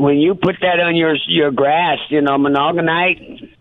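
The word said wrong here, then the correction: Milorganite: I hope I'm pronouncing it right, but you know, it's M-I-L-O-R-G-A-N. – Yeah, it's close enough. Mill organite.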